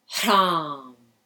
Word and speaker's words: Hram: So the first one is Hram, long A, that aspirate in the beginning.